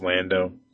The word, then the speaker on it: Lando: And it uses a load balancer for reverse proxying back to the containers called Lando. So, and that's, it's like LNDO, but it's pronounced Lando.